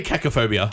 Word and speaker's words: cacophobia: Cacophobic. – I'm going to say cacophobia.